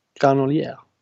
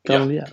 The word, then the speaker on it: Granollers: Jag vet inte hur det uttalas. Nere i Spanien. – Granolier. – Granolier. Ja, granolier. Granolier säger man ibland.